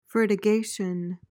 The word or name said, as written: fertigation